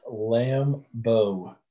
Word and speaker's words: Lambeau: It's pronounced Lambeau. – Like Guinea? Yes. – Like a Lamborghini.